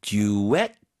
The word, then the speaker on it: duet: A beautiful duet. 뒤쪽에 힘 주세요. 발음 주세요.